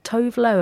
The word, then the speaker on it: Tove Lo: So the next song we've got up is Stay High by Tove Lo. Have I said that right? – Yes, you have.